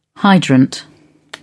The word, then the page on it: hydrant: British English